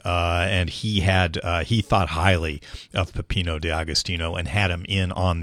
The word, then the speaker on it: Peppino D'Agostino: It is Peppino D'Agostino. Peppino D'Agostino.